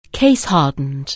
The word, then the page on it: case-hardened: anglais